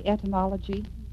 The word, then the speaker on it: etymology: Did I say etymology? – I believe you did. That loses us 1 point there. – Pardon me? – That loses us 1 point. She did pronounce it correct.